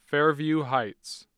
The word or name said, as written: Fairview Heights